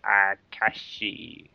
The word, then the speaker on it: Akashi: One's called Montopia, and one's called Akashi. that's the Japanese pronunciation.